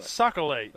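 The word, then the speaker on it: sac-a-lait: Socolate. That's how the Cajuns pronounce that fish.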